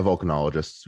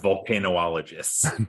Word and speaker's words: volcanologists: Yeah, volcanologists. – Okay, good. – Not volcanoologists.